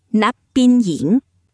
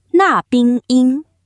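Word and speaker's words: นักบินหญิง: 泰语是 Nak Bin Ying，发音是那冰音。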